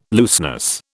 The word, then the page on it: looseness: British English